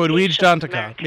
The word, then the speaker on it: Edwidge Danticat: And one of my favorite authors, I hope I'm pronouncing her name correctly, Edwidge Danticat. She's a... – Oh, Edwidge Danticat. Yes, yes.